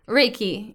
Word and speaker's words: Reiki: Reiki? – Reiki, yeah.